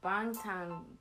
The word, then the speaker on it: Bangtan: Bang. – No, Bangtan.